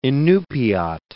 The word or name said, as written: Inupiat